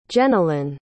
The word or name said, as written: Jenalyn